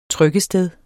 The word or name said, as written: trykkested